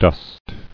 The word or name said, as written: Dost